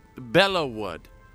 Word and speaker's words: Belleau Wood: entry into the war, including the famous Battle of Belleau Wood in France. Hope I said that right. – Belleau Wood.